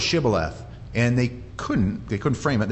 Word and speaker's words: Shibboleth: Say Shibboleth, and he said Sibboleth, for he could not frame to pronounce it right. See, they have an accent. The Ephraimites, they can't say sh. They say s when they're supposed to say sh.